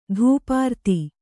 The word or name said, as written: ಧೂಪಾರತಿ